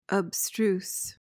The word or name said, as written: abstruse